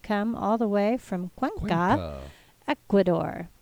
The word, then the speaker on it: Cuenca: So we think Cuenca is right. It's spelled C-U-E-N-C-A.